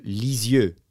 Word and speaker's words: Lisieux: That is me trying to do an uh, like a, an English accent, an English pronunciation. If you pronounce it the way it is pronounced in France, where she's from, it would be Sainte Thérèse de Lisieux.